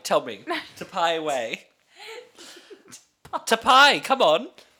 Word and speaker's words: Taipei: On July the 28th, 2013, in Tapai, Taipei? – I'm having an absolute, how do you say it? – Taipei. – Taipei.